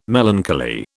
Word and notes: melancholy: British English

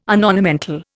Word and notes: unornamental: Indian

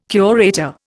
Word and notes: curator: Indian

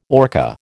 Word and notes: orca: American English

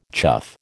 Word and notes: chough: American English

